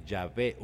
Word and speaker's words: Yahvé: La pronunciación más correcta, sin embargo, sería la de Yahvé.